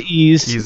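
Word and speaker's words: Ys: But the whole problem with that game on Master System was they put an apostrophe between the Y and the S, which is not how it's said. So, Ease is like a mythical land, basically, in French, I think. and that's how it's pronounced.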